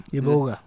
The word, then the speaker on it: iboga: I forgot how you pronounce it. – Ibogin. No? Iboga. – Iboga, yes.